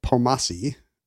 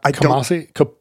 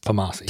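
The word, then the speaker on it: Kpomassie: His name has a silent K. It starts with a KP. – Like, and that's not, and you don't pronounce it? It's not Kamasi? – Like so many great Beetle Bailey comics, it begins with KP. But I don't think you say the K.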